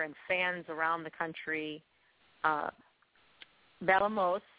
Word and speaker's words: bailamos: Well, it's funny because that song, like, the way you pronounce the word by itself, when you say it, is different than the way that he sings it. Because, like, the word, it means, like, we dance. But you would pronounce it bailamos. Like, that's how you would say, like, an accent over the A, that first A, like, the bailamos. So that's how you say the word.